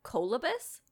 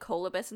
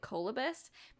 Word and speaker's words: colobus: I actually looked up the pronunciation for four different sites tonight before I recorded this episode, and they all said colobus.